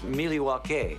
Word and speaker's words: Milwaukee: Actually, it's pronounced Milwaukee, which is Algonquin for the good land.